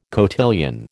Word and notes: cotillion: American English

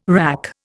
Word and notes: wrack: Indian

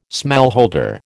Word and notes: smallholder: American English

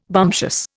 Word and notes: bumptious: Indian

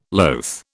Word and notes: loath: British English

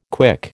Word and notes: KWIC: American English

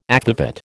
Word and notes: akvavit: American English

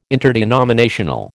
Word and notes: interdenominational: American English

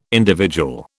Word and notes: individual: British English

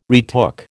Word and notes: retook: American English

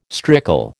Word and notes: strickle: American English

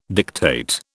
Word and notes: dictate: British English

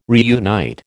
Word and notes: reunite: American English

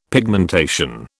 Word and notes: pigmentation: British English

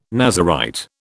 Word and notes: Nazarite: British English

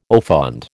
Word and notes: au fond: American English

